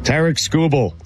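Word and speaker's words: Tarik Skubal: Tarek Skubal.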